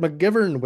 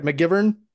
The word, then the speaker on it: McGivern: And new to the show, but not new to the Positively Wolfie podcast, as is Brother Clint, we have Mike McGivern with us. – Yeah. Is this moron number one? Put moron number two on the phone. – Hey, thanks for having me, fellas. – I said that right, McGivern? – Yeah, you nailed it.